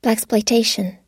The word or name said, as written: blaxploitation